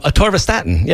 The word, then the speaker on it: atorvastatin: Recently suggested an alternative to atorvastatin. Did I pronounce that right? – Atorvastatin.